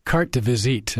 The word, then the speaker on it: carte de visite: The carte de visite, am I pronouncing that correctly? – Yes.